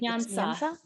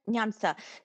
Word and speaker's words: Nyansa: Nyansa. – Is that correct? Nyansa.